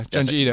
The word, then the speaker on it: Junji Ito: Yeah, Junji Ito.